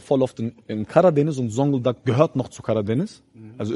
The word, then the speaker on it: Zonguldak: Ich komme aus Zonguldak. 6-7. – Woher, warte? – Zonguldak.